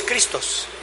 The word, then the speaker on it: Christos: Mashiach es la palabra en hebreo, y Cristo es una palabra que sale del griego al español, Cristo, porque realmente en el griego es Christos, no es Cristo, como nosotros lo pronunciamos.